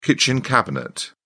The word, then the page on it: kitchen cabinet: British English